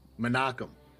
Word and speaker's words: Menachem: Is it Menahem? Menahem? That's it. What is it? – Menachem. – Wow, I would have never pronounced it like that.